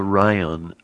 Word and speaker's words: Rion: But anyway, her name is Chanel Rion, R-I-O-N.